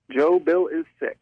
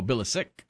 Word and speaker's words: Bilicic: Joe Bilicic. – Oh, Bilicic. Okay, I was pronouncing the C as a ch.